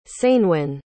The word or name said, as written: ceinwen